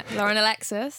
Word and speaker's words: Lauren Alexis: Lauren Alexis.